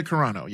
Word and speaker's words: Carano: And Gina Carano, is that how you say her name? – Gina Carano, yes.